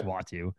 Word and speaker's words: Uatu: Online, if you go to the Wikipedia page, the pronunciation says WAH-TOO. So that to me is Watu.